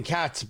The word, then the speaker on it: Katz: My name is Tony Katz, by the way. Last name is spelled K-A-T-Z.